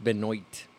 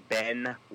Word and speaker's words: Benoit: I thought it was pronounced Benoit. – Well, if you look it up, it's spelled Benoit, but you pronounce it Benoit.